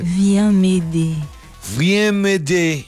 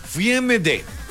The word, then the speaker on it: Viens m'aider: Pues bueno, esto proviene de la expresión francesa que se pronuncia básicamente de esta manera: por si te quieres mamasear y pedir ayuda en francés. – Viens m'aider. – Así tal cual. Viens m'aider.